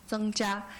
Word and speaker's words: Zheng Jia: My name is Jia Zhen, and then pronouncing Chinese is Zheng Jia.